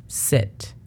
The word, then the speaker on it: sit: No hay que confundirla con el de sit down, porque aunque es muy poca la diferencia, sí la hay en la pronunciación. Una es seat y otra es sit. Esta significa asiento, aunque la pronunciación fonética sí es S-I-T, sit.